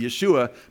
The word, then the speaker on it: Yeshua: There's no J's in Hebrew. So it wouldn't be Joshua, it would be Yeshua, but it would be just like we say Jesus in the Hebrew.